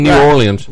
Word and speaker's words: New Orleans: There won't be empties in New Orleans.